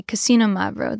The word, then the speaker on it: Xinomavro: Or a Xinomavro, which sounds like casino, but close. It's with an X. So just say Casino Mavro. That's basically how it sounds.